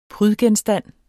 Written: prydgenstand

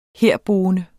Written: herboende

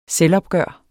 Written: selvopgør

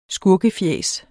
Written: skurkefjæs

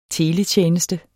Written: teletjeneste

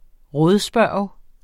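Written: RÅDSPURGT

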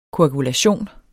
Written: KOAGULATION

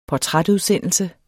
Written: portrætudsendelse

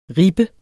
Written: ribbe

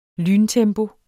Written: lyntempo

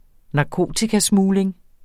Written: narkotikasmugling

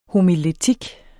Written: homiletik

